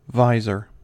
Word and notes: visor: American English

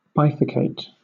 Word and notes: bifurcate: British English

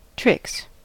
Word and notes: tricks: American English

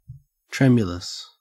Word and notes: tremulous: Australian English

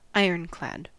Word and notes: ironclad: American English

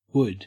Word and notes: wood: Australian English

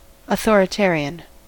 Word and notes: authoritarian: American English

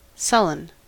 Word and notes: sullen: American English

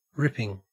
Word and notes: ripping: Australian English

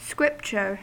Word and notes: scripture: American English

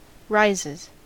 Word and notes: rises: American English

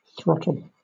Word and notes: throttle: British English

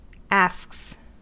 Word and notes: asks: American English

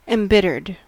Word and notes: embittered: American English

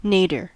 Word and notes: nadir: American English

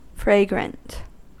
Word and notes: fragrant: American English

